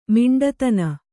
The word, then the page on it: ಮೊಂಡುತನ: Kannada